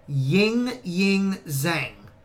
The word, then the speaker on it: Ying Zhang: Ying Zhang. Okay? So, yeah, I know. And that's not a mispronunciation.